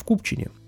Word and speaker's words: Купчине: И вот в Пушкине или в Пушкино, короче говоря, путаница, но правильно говорить, в Купчине. Угу.